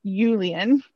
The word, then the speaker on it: Julian: And then today we find out the name, and it's Julian, but they pronounce it Julian in Swedish because J's do not make the typical sound.